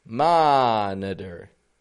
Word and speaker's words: monitor: How about monitor? Monitor. Now in here, we're seeing three levels of syllable stress. Stressed, ma. And then the N-I, we're giving a N with a schwa. Ma-n-n. And then we're using some secondary stress on the der, on the flap T with the R.